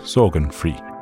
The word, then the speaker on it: Sorgenfri: The Danish title is Sorgenfri, which would be pronounced Sorgenfri.